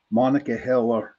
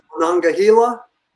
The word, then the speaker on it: Monongahela: Sorry, Johnny Freeman, Monongahela River. Okay. – Monongahela? – Yeah, Gila or Halo. Okay. Depends on what part of Pittsburgh you're from, the way I look at it.